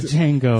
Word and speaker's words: Django: The Django. – The D is silent.